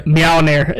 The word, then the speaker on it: Mjolnir: Pronounce it correct if you're going to say it. – Yeah, come on. – Mjolnir? – No, that was Mjolnir? Correct.